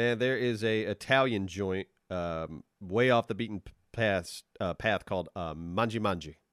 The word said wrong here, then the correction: Mangia Mangia: I think Mangia Mangia is how people pronounce it. Mangia Mangia. But anyway. – Yeah, that makes more sense.